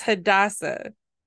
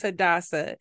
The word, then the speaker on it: Hadassah: So Esther's real name was Hadassah. That's how you say it. So her real name was Hadassah, and they called her Esther.